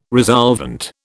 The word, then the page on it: resolvent: British English